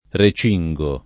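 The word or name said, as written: recingere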